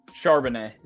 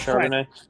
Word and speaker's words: Charbonnet: Charbonnet, that's how you pronounce his name, right? – Charbonnet. – Yeah, I know it sounds Charbonnet. French. Chardonnay, whatever.